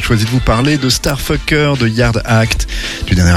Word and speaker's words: STRFKR: Ça s'écrit S-T-R-F-K-R, mais il faut prononcer Starfucker.